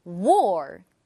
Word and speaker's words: war: 전쟁은 war. War. 이 단어는 조금 어려운데요. R 발음도 어렵지만 W 발음도 어려운 발음이에요. 자, 입을 작고 동그랗게 모아주고 입 속 공간을 넓혀주고 w, w 소리를 내는 거예요.